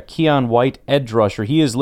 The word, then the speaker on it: Keion: 285 pounds out of Gardner, North Carolina, 24.2 years old, a little bit older, pronounced Keon, that's pretty straightforward.